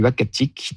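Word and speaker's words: Uhackatic: Et donc, on s'est mis d'accord sur Wakatik, qui désigne un endroit propice à la chasse du caribou.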